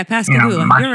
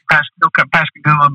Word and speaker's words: Pascagoula: It would have been Pasagula, or, you know, I'm sure I butchered that. – Yeah, Pascagoula. – Pascagoula, but there were so many decoy factories there back in the day.